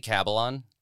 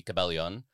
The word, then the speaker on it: Cabellon: I say Cabellon. – Cabellon. – Entirely phonetic. Rhymes with Babylon. – Yes, that's right. – I've been told by Filipinos I say it wrong. I've been told in Tagalog it would be Cabellon. But then there's also like, you know, being conquered by the Spanish and it's like Cabellon.